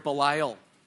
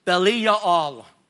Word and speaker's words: Belial: It means to profit and to gain, to make up the word Belial. The actual word would be pronounced Bali Ya'al, but we say Belial for our English rendering, Bali Ya'al.